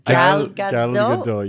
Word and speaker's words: Gal Gadot: Did I say it wrong? – Gal Gadot? – I think that's the right way you say it. – Wait, say it one more time. – Gal Gadot? – Gal Gadot, yes.